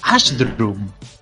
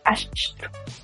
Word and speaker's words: Ashdrum: Ashdrum. – Ashdrum. – ¿Cómo se escribe eso? – A-S-H-D-R-U-M.